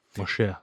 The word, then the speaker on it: Moshe: Moshe. Moshe, sorry. I was using the Greek pronunciation.